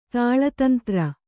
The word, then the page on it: ತಳತಂತ್ರ: taḷa tantra